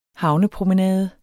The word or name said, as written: havnepromenade